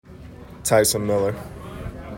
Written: Tyson Miller

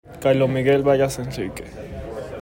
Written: Carlos Vargas

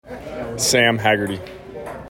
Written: Sam Haggerty

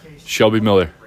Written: Shelby Miller

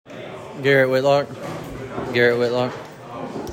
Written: Garrett Whitlock

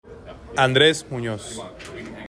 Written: Andres Munoz

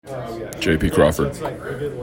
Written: JP Crawford

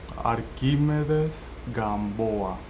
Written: Arquimedes Gamboa

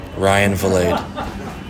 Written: Ryan Vilade

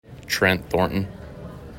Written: Trent Thornton

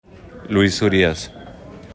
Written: Luis Urias

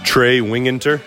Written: Trey Wingenter

